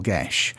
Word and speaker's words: Geas: The pronunciation of it technically apparently should be either Gish or Gesh. And the pluralization would be more along the lines of Gisha. But because of the way that Smith has spelt it here, that doesn't really work. So I'm actually more inclined to pronounce it Geases, even though that is technically incorrect.